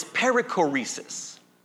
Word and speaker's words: perichoresis: But here's the word. It's perichoresis. Perichoresis. Let me spell it in case some of you care, all right? P-E-R-I-C-H-O-R-E-S-I-S.